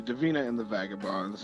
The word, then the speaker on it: Davina and the Vagabonds: Absolutely, Davina and the Vagabonds.